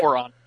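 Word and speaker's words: Ouran: I actually do know about, it's pronounced Oran, is that out? Is that it? – Oran High School Host Club, because Kellen Scrivens, if I remember correctly, has raved about that series several times, hasn't he? – He's a huge fan of that show.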